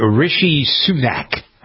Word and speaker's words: Rishi Sunak: it's Rishi Sunak.